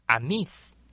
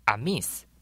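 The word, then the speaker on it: Amis: 阿美族的族语发音叫做阿密斯，阿密斯，或是有另外一种发音叫做邦扎，邦扎。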